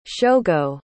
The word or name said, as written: shogo